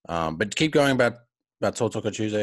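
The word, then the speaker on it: tautoko: Yes. Tau Toko. So it's Tau Toko.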